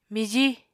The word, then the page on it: mijí: Apatani